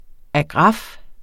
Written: AGRAFFERNES

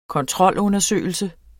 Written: kontrolundersøgelse

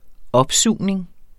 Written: opsugning

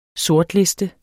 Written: sortliste

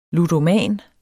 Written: ludoman

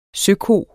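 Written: Søko

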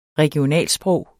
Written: regionalsprog

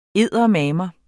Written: eddermame

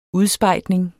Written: udspejdning

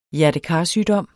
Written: hjertekarsygdom

